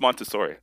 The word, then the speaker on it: Montessori: Yeah, it always rhymes with rotisserie in my head. – Montessori? – Yeah, that's not it? – I think it's Montessori. – Okay. Is it the same word, just like a different emphasis?